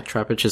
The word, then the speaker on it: Trapinch: Trapinch is Flygon, right? – Yes.